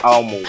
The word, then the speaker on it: ALMD: Okay, so to those of you who don't know what ALMO is, it's ALMD, but it's pronounced ALMO.